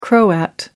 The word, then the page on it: croat: American English